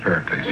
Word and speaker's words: Pericles: Well, first came the Athenians, and then Paracycles. – Well, I think that's pronounced Pericles.